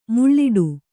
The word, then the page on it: ಮುಳ್ಳಿಡಿ: muḷḷiḍi